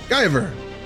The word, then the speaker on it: Guyver: Guyver? Guyver.